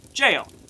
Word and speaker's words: gaol: Send him to Gao Now Jail. It's jail, isn't it? Right?" – Yes, it is jail, even though it's spelled stupidly. – Yeah, right? So it's pronounced with a G, not a J.